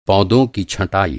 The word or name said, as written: छँटाई